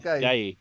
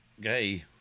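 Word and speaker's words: Guéhi: How do I say his name? Mark Gay. – Gay. All right.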